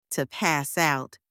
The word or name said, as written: to pass out